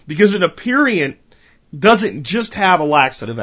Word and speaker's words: aperient: And that is aperient. Again, aperient.